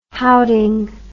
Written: houting

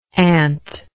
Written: antlike

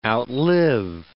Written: outlive

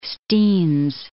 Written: Steens Mountain

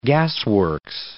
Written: gasworks

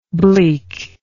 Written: bleakness

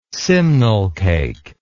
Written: simnel cake

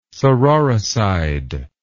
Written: sororicide

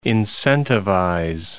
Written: incentivize

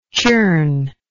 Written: churner

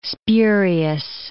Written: spurious

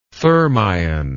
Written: thermion

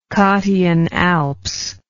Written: Cottian Alps

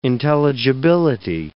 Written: intelligibility

